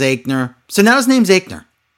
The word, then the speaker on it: Aichner: Fabian Aichner, that's how you pronounce it, Aichner, right? With an I. But it looks like Aichner.